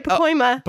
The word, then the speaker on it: Pacoima: In America, our top cities we have is Pacoima, California. – Yep, you said it right. Pacoima.